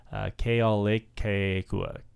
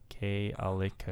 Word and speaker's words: Kealakekua: Kealakekua. Kealakekua.